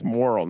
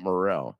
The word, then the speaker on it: Morril: It's Morril, not Morril.